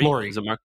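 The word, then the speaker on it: Lore: So we had this guy on the podcast named Mark, how do you say his last name? Lori? – Lori.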